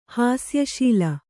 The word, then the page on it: ಹಾಸ್ಯಶೀಲ: hāsya śila